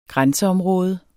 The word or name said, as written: grænseområde